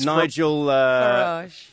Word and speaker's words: Nigel Farage: That's not how you say his name. – Wait, how do you say his name? – All right, your time is up. Your time is up. What is it? – It's Nigel Farage.